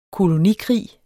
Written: kolonikrig

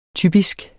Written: typisk

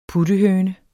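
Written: PUTTEHØNER

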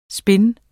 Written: spin

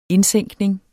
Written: indsænkning

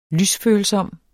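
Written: lysfølsom

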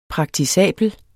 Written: praktisabel

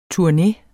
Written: Turne

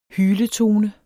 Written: HYLETONER

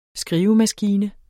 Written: skrivemaskine

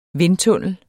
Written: vindtunnel

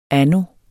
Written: anno

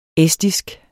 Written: estisk

